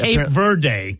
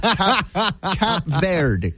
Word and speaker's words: Cape Verde: No, we're going with Cap Verde. Cap Verde.